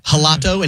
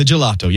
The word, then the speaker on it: gelato: And I thought it was like a soft G, like you might pronounce a J in Spanish. So I was like, I'll have the gelato. And he's like, ah, the gelato. Yes, yes.